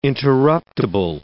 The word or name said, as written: interruptible